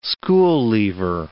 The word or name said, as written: school-leaver